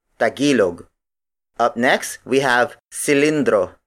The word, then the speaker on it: tagilog: Its native Tagalog counterpart is Tagilog. Tagilog. Tagilog. Tagilog is spelled as T-A-G-I-L-O-G.